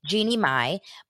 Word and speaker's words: Jeannie Mai: Well, I do know Jean Mai. Am I saying it right? Jeannie Mai, but I don't know her husband.